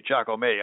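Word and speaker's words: Jacomet: That's J-A-C-O-M-E-T, Giacomet, I guess you'd pronounce that.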